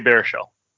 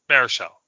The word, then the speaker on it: Baruchel: It stars Baruchel. Is that how you pronounce his name? – Yes, Jay Baruchel. – Baruchel, okay. I watched it because he's in it.